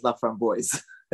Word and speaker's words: LaFramboise: So the, the actually the thing, the funny thing about pronouncing last names, he was from Chicago, so he pronounced his last name as LaFramboise.